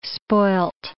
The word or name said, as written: spoilt